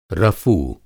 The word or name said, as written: रफ़ू